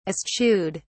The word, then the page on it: eschewed: British English